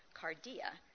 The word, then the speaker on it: kardia: And the Greek word for heart, which is K-A-R-D-I-A, pronounced cardia, figuratively means thoughts or feelings.